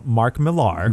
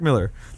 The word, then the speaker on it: Millar: But it's made by Mark Miller, whose name is spelled with an A R, and I've been calling him Mark Millar for about 15 years. Nope, his name is Miller.